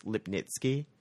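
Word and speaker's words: Lipnicki: Apparently, it's technically pronounced Lipnitsky. – Really? – Because it's Polish, but, I mean, no one pronounces it that way, including my family.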